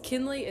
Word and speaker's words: Kinlee: My TikTok is Kinley Alexis. Kinley is K-I-N-L-E-E, not K-E-N-L-E-Y. – Don't ever mispronounce her name or misspell it when you're talking to her. – It's Kinley Alexis _.